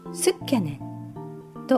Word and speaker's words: 好きやねん: 好きな彼女に関西の男性が言う好きやねんという言葉、これはすっきゃねんと発音します。